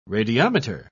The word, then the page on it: radiometer: British English